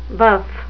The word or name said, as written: buff